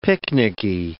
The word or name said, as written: picnicky